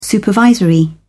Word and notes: supervisor: American English

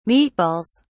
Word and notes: meatballs: British English

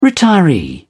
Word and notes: retiree: British English

